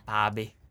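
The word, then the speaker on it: Bhabie: Because the way that it's spelled does make you want to say it like Ba. – Bobby.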